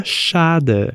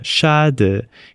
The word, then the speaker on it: schade: No alemão não é diferente, mas a forma escrita dele faz parecer que a pronúncia seja complexa, o que não é verdade. Para ilustrar isso, vamos pegar como exemplo a palavra schade, schade, que significa pena.